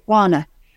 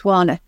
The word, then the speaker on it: Juana: Well, Juana? I would just say Juana. I think it's Juana.